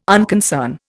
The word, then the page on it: unconcern: Indian